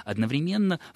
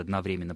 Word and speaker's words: одновременно: В какую-то эпоху считалось, что правильнее, лучше говорить одновременно, в какую-то одновременно. Я предпочитаю тоже одновременно, потому что эта форма, это ударение поддерживается однокоренными и созданными по такой же модели словами, как современно, своевременно, да, и так далее.